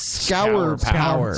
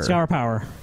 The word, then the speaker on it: Scour Power: This is, I don't even know how to say it, S-C-O-U-R. – S-C-O-U-R. – Scour Power. It's Scour Power. – Scour Power.